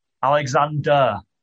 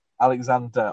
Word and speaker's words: Alexander: Um, Ollie, okay, how how do you say his last name? Alexandra. – Alexander. – Alexander. Ollie Alexander.